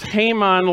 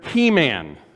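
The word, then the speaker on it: Heman: and it's pronounced Haman like the Bahamian greeting, right? Haman, not like the overly muscular action figure He-Man, okay?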